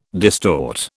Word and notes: distort: British English

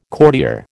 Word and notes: courtier: American English

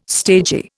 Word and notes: stagy: Indian

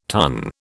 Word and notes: tun: British English

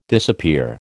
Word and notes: disappear: American English

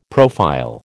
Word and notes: profile: American English

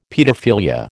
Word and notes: paedophilia: American English

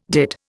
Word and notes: dit: Indian

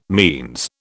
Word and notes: means: British English

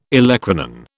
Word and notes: olecranon: British English

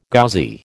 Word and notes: Ghazi: American English

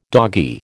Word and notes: doggy: American English

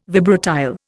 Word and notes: vibratile: Indian

